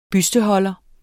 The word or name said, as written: busteholder